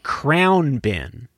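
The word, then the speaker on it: Khruangbin: I said, well, first of all, how do you pronounce that name? Krugbin? Apparently it's something like Crown Bin. – Oh, okay. So the woman introducing got it wrong too. – Yeah, without pronouncing the G.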